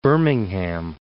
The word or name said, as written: Birmingham